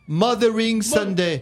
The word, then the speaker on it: Mothering Sunday: En Angleterre, la fête des mères est appelée Morsning, qui s'écrit M-O-R. – Oh là là, fais voir ta feuille. – Morsning, non, c'est... Non, voilà, Morsering, où ça? Fais voir, où ça? Mothering Sunday. – Voilà, Mothering Sunday, voilà.